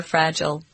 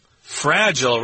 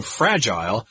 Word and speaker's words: fragile: If you have glasses or glassware or pots, if you let them fall, they're going to break because they're fragile. – Fragile, right. That's the American pronunciation. You'll probably hear Canadians and British people saying fragile. But in the USA, we say fragile.